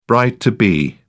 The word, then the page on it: bride-to-be: British English